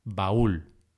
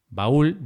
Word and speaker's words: baúl: Ba-ul, baúl. Baúl lleva tilde en la U. Ba-ul, baúl, para marcar el hiato.